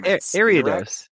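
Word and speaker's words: Ariados: Eridos evolves from Eriados. – Eriados? That makes way more sense because there's an I in it. Yeah, Eriados.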